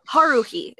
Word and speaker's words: Haruhi: I know it's H A U H A R U H I.